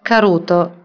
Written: acenoria

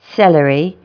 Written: celery

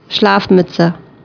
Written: Schlafmütze

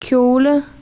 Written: abito da donna

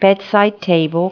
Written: bedside table

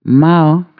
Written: fini

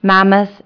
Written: mammoth

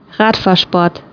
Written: Radfahrsport

